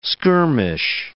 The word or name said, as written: skirmish